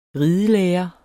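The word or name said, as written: ridelærere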